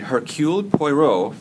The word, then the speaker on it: Hercule Poirot: In which Agatha Christie novel did Hercule Poirot first appear? Did I pronounce that right? – Yes.